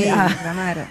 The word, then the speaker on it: Negramaro: Negramaro. – Negramaro, ah, oui. Negramaro.